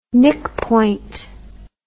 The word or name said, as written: knickpoint